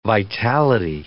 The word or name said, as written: vitality